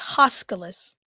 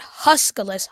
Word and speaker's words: Huskalus: So, did you say Huskalus? It's Huskalus. – I don't hear a difference. – It's spelled with an O. Huskalus, not Huskalus.